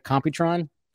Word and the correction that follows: Competitron: I think, uh, or, comp- uh, Competitron. – Competitron. Yeah. yeah, that's right.